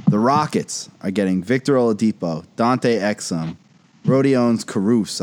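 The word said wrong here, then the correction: Kurucs: I don't know if I said that right. Karuks. – Karuks. – Yeah, thank you, Sully.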